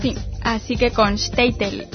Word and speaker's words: Shtetl: Y él nos puntualiza que en Europa Oriental, en Yiddish, el modo de pronunciar correcto es Shtetl.